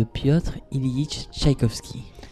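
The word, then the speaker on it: Pyotr Ilyich Tchaikovsky: de Piotr Ilyich Tchaïkovski.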